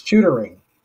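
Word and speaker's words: Shootaring: Just so I don't butcher the name, can you tell me how to pronounce the name of the mill? – It's Shootering. – Shootering. – Yes.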